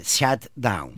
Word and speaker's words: shutdown: que se pronuncia shutdown.